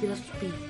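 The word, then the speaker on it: Graf Spee: Spe sería si fuera en inglés y está en alemán. Es Graf Spe. – La mayoría de las palabras que he escuchado con S pronuncian. – Graf Spe, de hecho, es en realidad. – Sí, la S se pronuncia.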